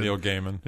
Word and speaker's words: Gaiman: Neil Gaiman.